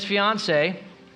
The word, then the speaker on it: fiancée: Yes, and it rhymes with Beyonce. That's right. All right, smart kids. So Joseph and his fiance, rhymes with Beyonce, left Nazareth, a village in Galilee, and journeyed to the hometown in Judea, Bethlehem, King David's ancient home.